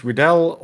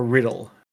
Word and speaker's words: Riddell: John, I think it's either it's called, pronounced Riddell or Riddle. R-I-D-D-E-L-L. Riddell, maybe. But, like, the only reason why I know that is because I looked it up when I started thinking about this episode.